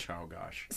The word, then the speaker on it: Czolgosz: Czolgosz.